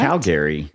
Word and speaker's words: Calgary: Actually, some friends met a bunch of Canadian teens in a hot tub in Canada one time, and they all pronounced it Calgary. – What? – Calgary. – Yeah. So I don't know. – That's correct.